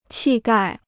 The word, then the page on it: 气概: qì gài